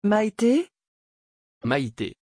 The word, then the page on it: Maité: French